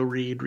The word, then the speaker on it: Reid: Um, you can also find me on Twitter at Joe Reid. Reid is spelled R E I D.